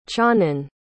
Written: cianan

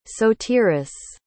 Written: sotiris

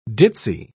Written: ditzy

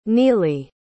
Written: neelie

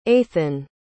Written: athan

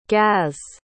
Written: gaz

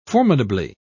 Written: formidably

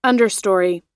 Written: understory

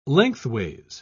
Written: lengthways

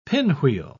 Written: pinwheel

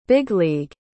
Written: big-league

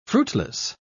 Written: fruitless

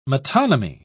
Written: metonymy

